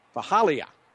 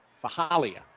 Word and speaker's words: Faalii: And I tell you, if you're listening along, well, you obviously listen on one side, it is spelled F-A-A-L-I-I. But it's pronounced Fahalia. Fahalia. Gavier Fahalia. Say it a few times, it'll be smoother.